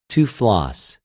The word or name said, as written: τυφλος